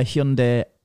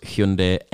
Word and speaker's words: Hyundai: Is that how you pronounce Hyundai? – Hyundai. – We pronounce it Hyundai over here. Yeah, that's what I meant. – Hyundai. That's how the Germans pronounce it. – Okay. – It's how it's properly pronounced.